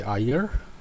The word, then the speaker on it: Iyer: I'm Dr. Ravi Iyer. Name is spelt a little bit off. It's I-Y-E-R.